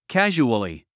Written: casually

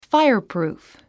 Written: fireproof

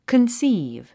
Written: conceive

